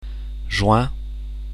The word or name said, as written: juin